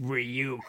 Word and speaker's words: Ryuk: Ryuk. – Ryuk, as Willem Dafoe corrects him. It's pronounced Ryuk.